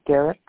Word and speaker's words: Derrick: Derek, okay. You spell it with C-K or just K? – C-K, Derek. – Okay. Okay. – D-E-R-R-I-C-K.